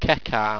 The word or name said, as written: cekarm